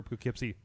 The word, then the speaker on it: Poughkeepsie: What is the affectionate short term for Poughkeepsie, New York? – Poughkeepsie.